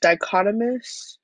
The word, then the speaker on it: dichotomous: A consistent analyst using 45 dichotomomes. I can't pronounce that. This word means exhibiting or characterized by to show me on how to pronounce that. It's pronounced dichotomous.